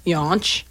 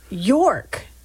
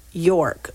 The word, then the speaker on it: Yauch: Yonch. – York. It's spelled Y-A-U-C-H. – Yonch. – It is pronounced York, like as in pork.